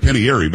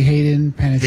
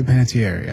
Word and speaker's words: Panettiere: I usually say Hayden Panettiere. – Hayden, what did I say? Peyton Hayden. Hayden Panettiere. – I say Panettiere, yeah. – Panettiere. It's an Italian name.